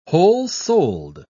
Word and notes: whole-souled: British English

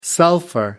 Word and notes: sulfur: American English

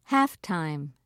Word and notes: halftime: British English

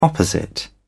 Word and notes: opposite: British English

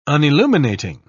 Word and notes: unilluminating: British English

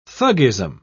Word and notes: thuggism: British English

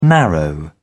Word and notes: narrow: British English

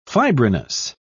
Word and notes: fibrinous: British English

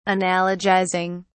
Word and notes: analogizing: British English

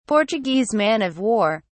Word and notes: portuguese man-of-war: British English

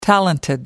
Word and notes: talented: American English

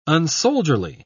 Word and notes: unsoldierly: British English

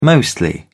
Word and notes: mostly: British English

